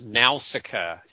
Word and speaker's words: Nausicaa: It's pronounced Nausicaa, Kelly. Don't let Dingus confuse you with his extra syllable. – No, we were told it was pronounced with a short A and then a long A at the end.